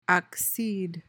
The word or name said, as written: accede